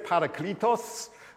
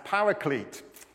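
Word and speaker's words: parakletos (παράκλητος): Tim was saying, I'm going to pronounce a Greek word for you. It's here, it's parakletos. You may have heard this word kind of anglicized as paraclete.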